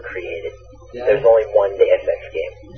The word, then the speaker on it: Deus Ex: Deus Ex.